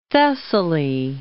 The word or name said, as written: Thessaly